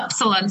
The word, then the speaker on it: Upsilon: So would you guys say Upsilon? Is that how you say it? – Yeah, Upsilon Circuit.